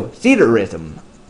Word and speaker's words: Theatrhythm: That's spelled, uh, let's see, T H E A T R H Y T H M, all one word. Uh, I would have pronounced it theatrhythm, but theater rhythm, I guess, makes a lot more sense with the meaning of the name. So, theater rhythm.